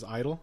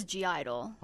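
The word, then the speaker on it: (G)I-DLE: Because I just found out recently that the G is silent. – So it's Idol? – Yeah. I always thought it was G-Idol.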